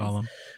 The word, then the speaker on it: Gollum: is it Gollum? Mm-hmm. I don't do Lord of the Rings. – Gollum.